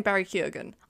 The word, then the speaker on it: Barry Keoghan: Oh, can we say off the bat, we're pronouncing his name Barry Keoghan. I don't know if that's right. – We Googled it the other day, that is right.